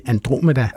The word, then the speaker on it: Andromeda: Og så ved jeg, at vi kalder den også for, og jeg ved faktisk ikke, hvordan man udtaler det, er det Andromeda-galaksen, eller er det Andromeda? – Ja, det er det sidste.